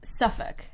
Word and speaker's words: Suffolk: Did I say that right, Englishwoman? Suffolk? Mm-hmm. Suffolk? – Suffolk. – Suffolk. – I think. – Okay. I don't know. I'm not English. I can't pronounce it the right way. You looked at me like you never heard that word before. – Well, I was thinking, I was like, what? But I think it's Suffolk.